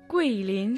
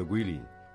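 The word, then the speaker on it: Гуйлинь (桂林): Гуйлинь шэн шуй джя тян ся. – Разберем название города Гуйлинь. Гуй означает «османтус» или «деревья османтуса». Произносится в четвертом тоне. А линь – это лес. Произносится во втором тоне.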